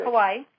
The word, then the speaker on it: Kauai: Kauai.